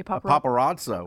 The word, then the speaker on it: paparazzo: Here's a quote from one of the paparazzo, is that how you say it? – A paparazzo. – A paparazzo. – Yeah, singular.